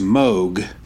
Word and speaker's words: Moog: His name is pronounced Moog, rhymes with rogue or vogue.